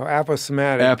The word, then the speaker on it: aposematic: I want to ask you, explain the word, I think I'm pronouncing this correctly, aposematic. – Oh, aposematic.